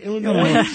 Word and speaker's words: Illinois: How do you say Illinois? – Illinois, without the S.